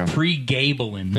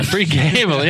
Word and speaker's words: pregabalin: I think it's pronounced pregabalin. – Yeah, pregabalin. Yeah, that's what it is.